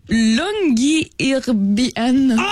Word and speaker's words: Longyearbyen: Ça s'appelle Longyearbyen. – T'es passé tellement proche, mon gars. – Je pense que c'est vraiment la prononciation exacte.